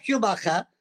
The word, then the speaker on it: Schumacher: I hope I'm saying the last name right, of E.F. Schumacher on your work. Yes, E.F. Schumacher, correctly you pronounced, was an economist.